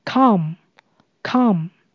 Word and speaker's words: calm: Calm，calm。